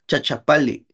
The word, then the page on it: chachapali: Náhuatl